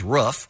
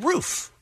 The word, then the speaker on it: roof: While people born and raised in the West tend to pronounce the word as if it rhymes with hoof, in other words, roof, those from the East see it as rhyming with poof, as in roof.